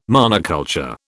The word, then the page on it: monoculture: British English